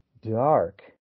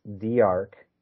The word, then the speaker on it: d'Arc: The D'Arc, which is actually how it's pronounced, it's D'Arc family, was devout Catholics, and Joan's mother, Isabel, took her children to church and confession often.